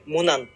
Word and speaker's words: MONIN: モニンって書いて、モナンって読む。